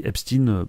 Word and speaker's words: Epstein: Je rappelle qu'on dit Epstein parce que c'est un Américain. On dit Einstein parce qu'il était allemand. Mais normalement, pour Epstein ou pour Weinstein, on dit comme ça parce que c'est comme ça qu'on prononce ça aux Amériques.